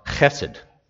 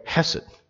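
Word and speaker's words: chesed (חסד): you have to use a guttural sound at the beginning, you know, chesed. But I don't want to spit on anybody, so I'm just going to say hesed, okay?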